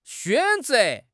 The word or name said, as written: xuan3 ze2